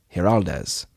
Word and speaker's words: Giraldez: Giraldez. Is G in Spanish an H? People call him Angel Giraldez. – Yeah. – I know Alfonso Giraldez. That's how you pronounce that last name.